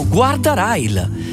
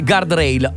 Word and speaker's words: guardrail: Pronuncia in italiano si legge come si scrive, ovvero guardrail. E questo è come lo pronunciano in tanti. Poi se uno vuole pronunciarlo in inglese si pronuncia guardrail.